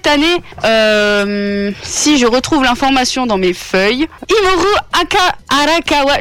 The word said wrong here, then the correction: Hiromu Arakawa: je sais pas comment ça se prononce, c'est abusé. Hiromu Arakawa, c'est la mangaka de Full Metal Alchemist, en effet, qui vient à Japan Expo cette année, qui n'est pas invité d'honneur.